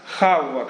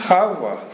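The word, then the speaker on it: Хавва (חַוָּה): Терах, Хавва. Кто скажет, что это Ева? Хавва. Кто скажет Терах, что это Фарра? Мы не знаем, мы неправильно произносим имена Авраама.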